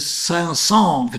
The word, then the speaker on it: Saint-Saëns: Juni trete ich auf, und zwar mit dem klassischen Werk von Émile Saint-Saëns, wird das ausgesprochen, ja.